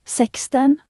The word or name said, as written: 16